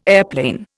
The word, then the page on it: airplane: Indian